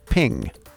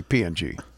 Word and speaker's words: PNG: The creators of the PNG image file format wanted it to be pronounced ping, but there's no I in it, so people just say PNG.